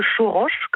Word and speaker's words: Choroche: Ce sont les habitants du village de Choroche, comme vous l'avez très bien prononcé.